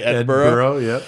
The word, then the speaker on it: Edinburgh: So in June 1836, a group of boys went rabbiting on Arthur's Seat, which is an extinct volcano in Edinburgh, Scotland. Isn't that how you say it, right? – Edinburgh, yeah.